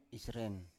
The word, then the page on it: isren: Biak